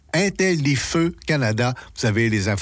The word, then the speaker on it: Intellifeu: et ça s'écrit comme ça se prononce, Intellifeu, donc Intel, L-I-F-E-U, pour le site web au complet, là, Intellifeu Canada.